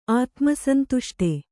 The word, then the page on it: ಆತ್ಮಸಂತುಷ್ಟ: ātmasantuṣṭa